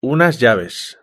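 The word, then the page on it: unas llaves: Spanish (Spain)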